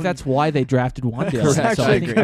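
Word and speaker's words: Wan'Dale: The Giants, Wandale Robinson went to the Giants, and that's how you say his name. – Wandale.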